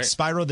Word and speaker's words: Spyro: Spyro the Dragon. Am I saying that right? – Yeah, you're saying it right. – Spyro the Dragon.